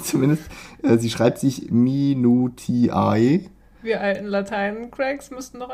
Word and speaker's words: Minutiae: Äh, ich würde es aussprechen Minutiae. Also, dass man das AE am Ende wie Ä spricht.